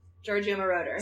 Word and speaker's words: Giorgio Moroder: And was written and produced by this incredible producer named Giorgio Moroder. Moroder. – Giorgio Moroder. – Say it properly. Thank you.